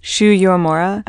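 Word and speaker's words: Shu Uemura: And I see you have the Shoe Mara lash curler. – Yeah. – Did I say it? Can someone say it right? – Shoe Yomora. – Oh, thank you.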